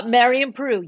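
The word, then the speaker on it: Marian Paroo: Did I say that right? – Yeah, Marion Peru. Yes, you did.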